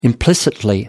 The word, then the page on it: implicitly: British English